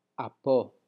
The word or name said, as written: apo